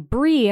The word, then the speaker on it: Brie: I feel bad because when I tell people my name is Brie and they're like, what? I'm like, Brie. And then I'll always go, like the cheese. And then I realize, oh, they're going to spell it B-R-I-E now.